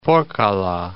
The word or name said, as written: Porkkala Peninsula